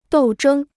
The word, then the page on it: 斗争: dòu zhēng